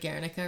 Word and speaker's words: Guernica: It's like when he made like Guernica or something.